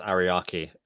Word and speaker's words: Ariake: And they're putting it at a pretty big Tokyo's Araki. Am I saying that right? – I think it's Ariake.